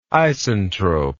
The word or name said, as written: isentrope